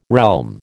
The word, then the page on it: realm: American English